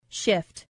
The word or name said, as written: shift